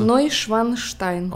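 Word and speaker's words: Neuschwanstein: Нойшванштайн.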